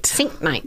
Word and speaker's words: Sienknecht: We say Sienknecht. – Sienknecht. – Yes.